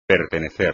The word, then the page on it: pertenecer: Spanish